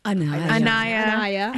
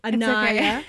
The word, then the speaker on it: Anaya: So how would you describe your characters, Shania? – Anaya. – I've got Shania written here, so I pronounce it right. Anaya.